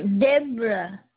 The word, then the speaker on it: Debra: Did you say J-E-D? – No, Deborah. – Oh, Deborah. – Yes. – D-E-B-R-A. – Yes, ma'am. – Then Sellers, S-E-L-L-E-R-S? – Yes, ma'am.